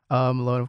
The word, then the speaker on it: Malone: Malone, of course, rhymes with Fatone.